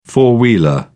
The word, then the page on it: four wheeler: British English